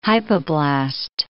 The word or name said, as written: hypoblast